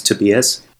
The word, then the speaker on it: Tobias: I'm from Germany, so the actual way to pronounce my name is Tobias.